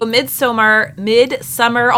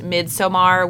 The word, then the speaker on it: Midsommar: Midsommar, although I've heard that Ari Aster says it's supposed to be pronounced Midsommar, which, like, that's hard for me.